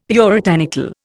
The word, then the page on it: puritanical: Indian